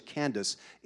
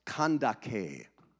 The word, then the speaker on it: Kandake: And by the way, it was not pronounced like we pronounce it in English, Candace. It was pronounced Kandake.